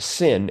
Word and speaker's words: sin: Actually, that would only be three letters. It would be pronounced sin.